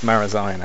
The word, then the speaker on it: Marazion: I think it's Marazion actually, rather than Marazion.